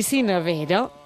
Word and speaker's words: sineuera: diuen Sineuer i sinevera, tot i que és correcte de les dues maneres.